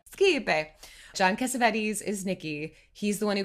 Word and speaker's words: Cassavetes: Am I saying John Cassavetes right? – Yeah.